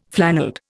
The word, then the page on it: flannelled: Indian